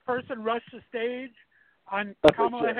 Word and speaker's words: Kamala: or Carmel, what is it? – Kamala.